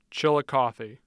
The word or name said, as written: chillicothe